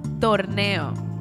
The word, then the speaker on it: torneo: So it's pronounced torneo.